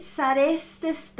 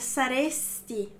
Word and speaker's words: sareste: Então, voi sareste state, ok? Então, pronuncia bem essa vogal final aí do sareste, porque se você colocar um i aí, já muda a pessoa, ó, vira saresti, ó, vira tu, não é?